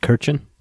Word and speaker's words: Kirchen: How do we say that? – Kirchen? – We do say Kirchen.